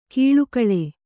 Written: ಕೀಳುಕಳೆ

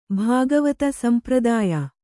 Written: ಭಾಗವತ ಸಂಪ್ರದಾಯ

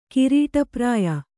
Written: ಕಿರೀಟಪ್ರಾಯ

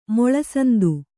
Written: ಮುಳುಸೌಂತೆ

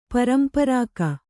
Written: ಪರಂಪರಾಕ

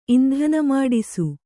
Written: ಇಂಧನಮಾಡಿಸು

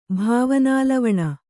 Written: ಭಾವನಾಲವಣ

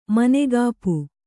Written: ಮನೆಗಾಪು

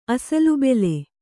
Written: ಅಸಲುಬೆಲೆ

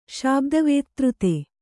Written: ಶಾಬ್ದವೇತ್ತøತೆ

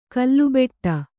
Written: ಕಲ್ಲುಬೆಟ್ಟ

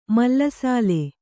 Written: ಮೇಲುಸಾಲು

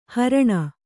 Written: ಹಾರಣಿ

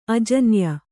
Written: ಆಜಾನೇಯ

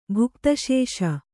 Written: ಭುಕ್ತಶೇಷ

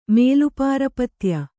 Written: ಮೇಲುಪಾರಪತ್ಯ

